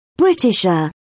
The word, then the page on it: britisher: British English